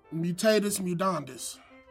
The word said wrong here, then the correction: mutatis mutandis: Wait, no, I said that wrong. – That almost sounded right, though. I almost believed it. – Mutatis mutandis? – Correct.